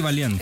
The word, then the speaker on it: Valiant: Eddie Valiant. – Eddie Valiant. Es que estaba pensando como un valiente, pero lo dije mal. Valiant.